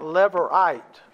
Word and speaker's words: Levirate: But it's pronounced, based I could figure out, Levirate.